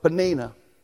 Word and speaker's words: Peninnah: The name of one was Hannah, and the name of the other was Peninnah. And that's how you say it.